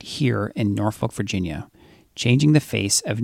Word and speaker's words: Norfolk: Yeah, the locals here, they don't use the R. It's Norfolk.